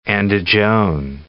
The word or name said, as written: Andijon